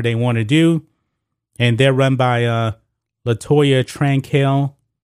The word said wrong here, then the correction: Cantrell: I believe that's the way you pronounce her name. Cantrell.